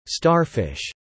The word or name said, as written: starfish